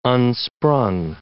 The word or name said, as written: unsprung